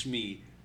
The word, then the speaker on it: Shmi: It's Shmi.